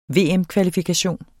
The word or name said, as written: VM-kvalifikation